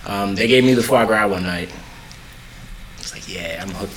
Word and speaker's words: foie gras: I made a spiced verju to go in the bottom of it, and the seared foie gras with a little crostini and stuff.